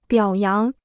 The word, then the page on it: 表扬: biǎo yáng